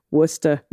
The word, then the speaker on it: Worcester: pronounce the last three with a st instead of a stir on the end. Glister, Worcester.